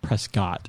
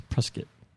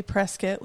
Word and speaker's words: Prescott: It's not Prescott, it's Prescott. – We say Prescott like biscuit.